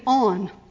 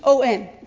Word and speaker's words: On: We have Korah and Dathan and Abiram and a guy named On. Just O-N. It's the easiest name in the Bible to pronounce.